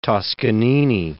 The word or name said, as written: Toscanini